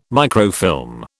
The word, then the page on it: microfilm: British English